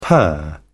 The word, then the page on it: per: British English